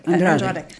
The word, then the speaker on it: Andrade: How do you say that? – Andrade. – Andrade.